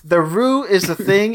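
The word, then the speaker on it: roux: A Rue, pronounced Rue, is browned in a mixture of white wheat flour and a cooking fat.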